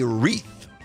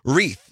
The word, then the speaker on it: wreath: Wreath. There's a silent W to start with. – There is. – W. So let's pronounce it. Wreath. The word of the hour is wreath. W-R-E-A-T-H.